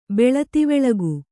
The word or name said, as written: ಬೆಳತಿವೆಳಗು